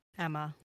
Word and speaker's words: Aemma: But we find out that his wife, is it Aemma? – Emma. – Emma? Okay. I can't remember how the A is pronounced in that.